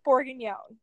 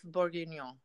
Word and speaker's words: bourguignon: It's I'm gonna butcher this because I'm not French, and it's I am. So go ahead. – Beef bourguignon. I think I said it right. That's right. – Yeah. – So oh yeah, you would probably French. – Say, Woof bourguignon. uh, no, no, buff, bur, yeah, buff bourguignon.